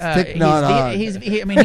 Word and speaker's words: Thich Nhat Hanh: It's Thich Nhat Hanh.